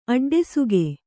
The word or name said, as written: ಅಡ್ಡಸೋಗು